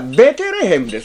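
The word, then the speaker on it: Bethlehem: あれはベテレヘムですね。ベテレヘムですが、ベテレヘム、ベテレヘムは英語的読み方ですね。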